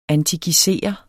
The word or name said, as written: antikisere